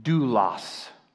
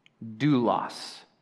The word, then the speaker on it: doulos: The Greek word used here for servants is pronounced doulos, doulos, a bond servant.